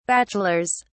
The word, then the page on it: bachelor's: British English